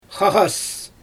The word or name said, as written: xaɣas